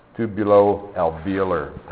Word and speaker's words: tubulo-alveolar: I see that O between them. Yeah, so always make sure you pronounce that O, right? Tubulo-alveolar type gland. All right,